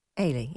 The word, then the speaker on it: Eilidh: So I was quite interested in this email from Ailey. Is that how you pronounce it? It's... because you've got Scottish roots, E-I-L-I-D-H. – Ailey, yeah.